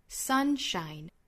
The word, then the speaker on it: sunshine: you are my sunshine， sunshine 这个词它的重读音节是在第一个音节上，我们读成 sunshine sunshine。